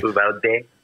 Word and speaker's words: Uvalde: Because, I think it's interesting because there was a, did you see that picture floating around, of Uvalde, I don't know how you say it. Sorry. – Uvalde.